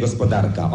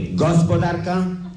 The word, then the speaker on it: gospodarka: On musi prędzej czy później postawić akcent fałszywie na pierwszej sylabie wyrazu, czyli pójdzie pod prąd języka ojczystego. On nigdy nie powie gospodarka, on powie gospodarka,